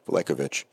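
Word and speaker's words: Veličković: Michael Graves and Bojan Velekovic, actually it's Velekovic. That's not how it's pronounced. There's a J in there. Even though there's no J in the spelling, there's a J in the pronunciation.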